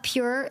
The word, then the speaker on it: Pjur: My I love Pure Lube. It's spelled P J U R.